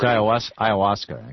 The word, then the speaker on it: ayahuasca: 8-0, they said that the government cannot hinder the use of peyote or of a tea called Hosca that has hallucinogenic. – It's pronounced ayahuasca, actually. – Oh, how do you say it? – Ayahuasca. – Ayahuasca, okay.